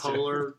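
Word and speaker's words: Poehler: No, it's the polar girl.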